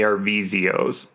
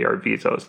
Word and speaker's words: Arvizo: Some pronounce them the Arvizios, but it seems like it's mostly the Arvizos.